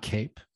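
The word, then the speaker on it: Kape: And you're saying it's not Cape? – It's Cap.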